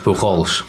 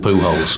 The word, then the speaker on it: Pujols: Clearly everyone knows that it probably should be pronounced Pujols. – Yeah. – But baseball commentators as a species have taken the executive decision that he should be called Pujols.